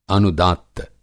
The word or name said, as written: अनुदात्त